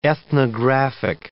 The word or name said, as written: ethnographic